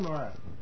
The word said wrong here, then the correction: Norrath: N-O-R-R-A-T-H. – Oh, so we say it wrong. It's Norath.